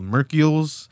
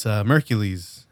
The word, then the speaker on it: Merkules: When I first saw who the fuck this guy was, when I first read his name, I thought it was called Mercules because it's spelled M-E-R-K-U-L-E-S. – Mercules. – But it's Mercules.